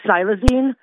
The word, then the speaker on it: xylazine: It's actually pronounced xylazine.